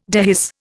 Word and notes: dehisce: Indian

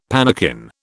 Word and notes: pannikin: British English

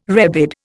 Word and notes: rebid: Indian

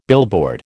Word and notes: billboard: American English

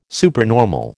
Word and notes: supernormal: American English

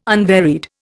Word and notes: unvaried: Indian

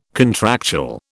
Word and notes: contractual: British English

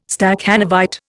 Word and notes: Stakhanovite: Indian

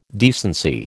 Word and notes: decency: American English

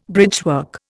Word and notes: bridgework: Indian

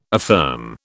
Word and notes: affirm: British English

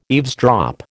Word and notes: eavesdrop: American English

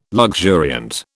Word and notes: luxuriant: British English

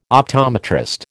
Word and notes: optometrist: American English

